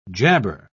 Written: jabber

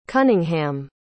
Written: cunningham

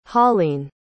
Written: holleen

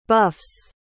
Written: buffs

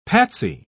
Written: patsy

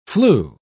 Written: flew

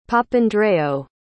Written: Papandreou

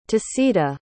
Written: tasida

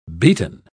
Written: beaten

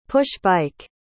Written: push-bike

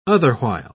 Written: otherwhile

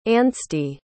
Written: anstey

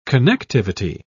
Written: connectivity

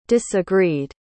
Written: disagreed

